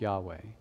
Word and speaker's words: Yahweh: So in the Hebrew, it is written with four letters, YHWH, and is often pronounced Yahweh.